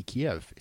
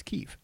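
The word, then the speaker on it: Kyiv: One of the biggest things to come out of that so far is that people actually are no longer pronouncing that city Kiev. It's Kiev. That's apparently how they pronounce it.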